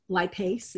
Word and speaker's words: lipase: Transformation Enzymes has one that's just lipase, and it's spelled L-I-P-A-S-E. And so you take that L-I-P-A-S-E. – A-S-E. A-S-E, Sam, as in Sam. L-I-P, like lip. Lip. – Okay. – A-S-E. A-S-E. – A-S-E. Lipase.